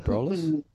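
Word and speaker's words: Abrolhos: the Ebrolus? – That's the one. You've got the pronunciation, yes.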